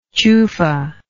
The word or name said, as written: chufa